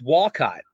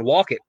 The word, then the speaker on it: Wolcott: I the town next to me in Bristol is pronounced I thought it was Walcott, W-O-L-C-O-T-T, when I moved up here. And then I started hearing all locals going, Walcott. I'm like, wait, what?